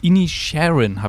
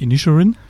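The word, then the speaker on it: Inisherin: The Banshees of Inisharin habe ich jetzt gelernt, nachdem ich den Film gesehen habe. Das heißt nicht Inisharin, wie, wie ich das erste Mal falsch gesagt habe, sondern Inisharin heißt es ist eine Oscar-nominierte schwarze Komödie,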